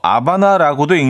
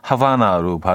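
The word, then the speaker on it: Havana: 아, 오경희 씨, 정답 주시면서 아바나라고도 읽는 것 같아요. 하셨습니다. 맞아요. H 발음을 안 하죠. 어, 그러니까 스페인 스페인어로 발음할 때 이게 아바나라고 하고 영어권에서는 하바나로 발음을 하고 뭐 똑같은 거죠.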